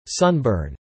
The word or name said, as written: sunburn